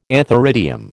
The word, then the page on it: antheridium: American English